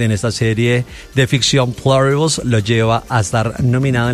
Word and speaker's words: Pluribus: No sé si así se pronuncia, Pluribus o Pluribus. – Pluribus. – ¿Cómo será en inglés? – Pluribus.